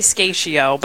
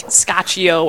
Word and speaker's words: Scaccio: Well, I say it wrong. If you're Italian, you laugh at me. We say Scaccio, but it's supposed to be like Scaccio or Scaccio or something like that.